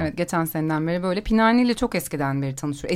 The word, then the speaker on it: Pinhani: Pinha, pinhani diyor okunuyor o Evet galiba.